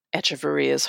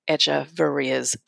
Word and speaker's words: Echevarria: So anyway, in between Echevarria's two plate appearances, and I'm not pronouncing the H because that's what it says on Baseball Reference. So I don't know if everyone else is pronouncing his name wrong or if Baseball Prospectus. Sorry, shout out to my workplace. Baseball Reference is wrong, but who knows? I'm going to go by their pronunciation.